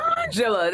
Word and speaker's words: Angela: Why you call her Angela? – That's how she pronounces her name. – Angela, that's how she says her name.